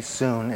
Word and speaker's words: sune: But it's diakonos with soon after it, sune, soon. It's S-U-N-E in the Greek, which actually is S-O-O-N is how you pronounce that.